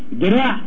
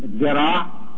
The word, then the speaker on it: ذراع: دراع الذال هي في اللغة العربية ذراع. الذال اللي هي بننطقها بين السنتين ذراع انتردنتال زي ما بيقولوا اصحاب الفونيتكس دي عملناها في المصري دال من دال الى دال.